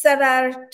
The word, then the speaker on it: Sever: So my full name is Sever Tok, S-E-V-E-R, which means to love.